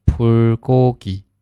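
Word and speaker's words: 불고기: 好，然后下一个是烤肉，汉语发音呢是 불 고 기， 불 고 기。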